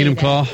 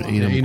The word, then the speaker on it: Enumclaw: Enumclaw, or how do you say Enumclaw. – That? – Enumclaw. Enumclaw.